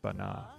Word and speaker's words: Banah: And then underneath us right now, we're listening to, from Bahrain, a song called Medley Al-Zaman Al-Jamil by an artist named, I believe is pronounced Bana, B-A-N-A-H.